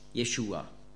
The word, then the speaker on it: Jeshua: Und Jesus ist also keine Übersetzung, sondern einfach die griechische Aussprache von Hebräisch Jeshua.